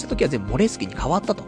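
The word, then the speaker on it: Moleskine: そしたら、こ、日本だけなんだってね、モールスキンっていう風に発音して読んでるのは。現地だと、発音の方はモレスキンっていう風に発音するらしくて、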